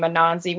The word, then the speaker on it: Anansi: We're sort of interchangeable with the name Anansi, which is not obviously the correct pronunciation. That's like the evolved pronunciation.